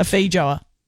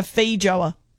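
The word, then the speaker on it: feijoa: A Faijua. – No. It said with the five E's. – Ella and Bree are right. You emphasise the E. A Faijua.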